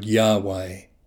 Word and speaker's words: Yahweh: Then bring your awareness to intentionally focus on the word Yahweh. As you breathe in, say the first syllable, Yah. Then, as you breathe out, say the second syllable, Way.